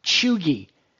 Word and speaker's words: cheugy: C-H-E-U-G-Y, chuggy.